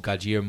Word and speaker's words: Gojira: Well, anyone who's a fan of the old Gojira movies or like... – That's how you pronounce that? – Gojira. – Oh, okay. – Yeah, it's spelled with a J.